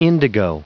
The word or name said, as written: indigo